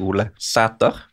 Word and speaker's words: Ole Sæter: Han heter selvfølgelig Ole Sæter. Ville ikke sagt Ole Gunnar Solskjær, for eksempel. Den æren skal uttales æ, så den må vi bare legge meg helt flat for.